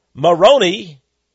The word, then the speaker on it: Moroni: If you make up something and you claim it's true, in fact, Joseph Smith said an angel called Moroni, M-O-R-O-N-I, that can also be pronounced Moron-I, I mean,